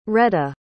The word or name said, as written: reda